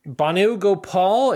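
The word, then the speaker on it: Bhanu Gopal: Banu Gopal. It is spelled B-H-A-N-U is Banu. Last name Gopal, G-O-P-A-L.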